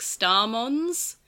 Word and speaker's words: Starmons: Yeah, in Cross Wars, there are some Digimon that kind of, like, exist in groups, like Starmons. So it's like a Z at the end. So it's pronounced Starmons, like, and Pikmons.